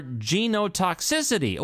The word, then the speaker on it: genotoxicity: So comorbidity has not been evaluated for the potential to cause carcinogenicity or genotoxicity.